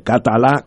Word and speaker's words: Catalá: Catalá con eh, acento en la A, Francisco Catalá, eh, eh, doctor en economía de Georgetown, si no me equivoco.